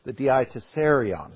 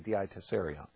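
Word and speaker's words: Diatessaron: It was called the Desiderion, the Dietiserion is what it was called. I said that wrong. The Dietiserion.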